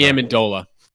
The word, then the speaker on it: Amendola: Just start Danny Amendola.